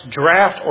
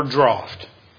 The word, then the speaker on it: draught: And I looked it up and it's pronounced draft or draught. Draft is the American pronunciation. Draft is the British.